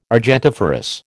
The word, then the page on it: argentiferous: American English